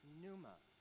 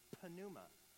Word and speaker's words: pneuma: The word for Spirit in Greek is pneuma. And if you were to pronounce it incorrectly, it would be pneuma.